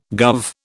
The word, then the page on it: guv: British English